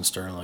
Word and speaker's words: Stirling: Should just be Austin Stirling.